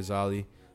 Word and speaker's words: Xalyli: Shout out to my boy Zali. Zali's it's spelled X-A-L-Y-L-I, or you can call him Zali Unknown, whatever you want to say, but however you want to say it.